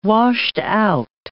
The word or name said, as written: washed-out